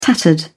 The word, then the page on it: tattered: British English